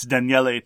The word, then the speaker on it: Daniele: It's Daniele Tascini. There are three syllables.